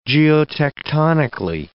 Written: geotectonically

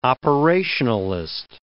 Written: operationalist

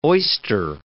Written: oyster

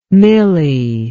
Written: millie

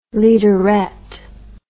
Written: leaderette

